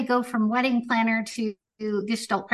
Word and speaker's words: Gestalt: What prompted you to the practice of gestalt, and specifically equine am I pronouncing it right, Gestalt, and explain to us what is Gestalt therapy and the role of horses in this practice. – Sure. And it's Gestalt, actually.